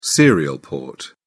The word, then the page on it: serial port: British English